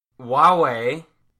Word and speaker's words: Huawei: Huawei, which is like the first time I've actually said that right, is already improving on the design.